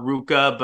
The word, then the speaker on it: RVCA: surf skate brand called Ruka, but the way they spell it is RVCA because of the like Latin characters or whatever.